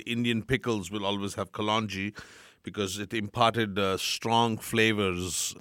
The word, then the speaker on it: kalonji: Nigella seeds, or is it kalonji? I don't know how to pronounce that. – Yeah, kalonji.